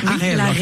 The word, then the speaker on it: Arwell: C'est toute la fin de semaine compétition équestre avec Arwell. – Avec la relle. – Arwell. Arwell. Oui, c'est comme ça que ça se prononce.